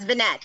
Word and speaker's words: Vignette: It's my husband's name, so it's Vignette. He's not French.